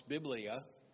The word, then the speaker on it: biblia: If I can speak Greek very well, maybe I don't, but I think it's pronounced biblia, B-I-B-L-I-A, meaning books, plural.